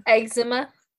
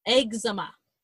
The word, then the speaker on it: eczema: No, that's not how you say it. How do you say that? The, like, flaky skin, when you're, like, your skin is super... – Eczema. – Eczema. That's the one.